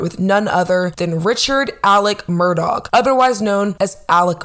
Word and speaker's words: Alex: I know I was definitely confused because it's spelled like Alex, but for some reason he pronounces it as Alec, almost as if there's a C at the end instead of an X.